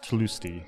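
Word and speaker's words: Tlustý: It's a Czech name. So actually, it's supposed to be pronounced Tlusty. So the long Tlusty. U.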